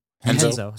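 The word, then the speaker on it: Renzo: Henzo. So also in Portuguese, all R's are pronounced H's.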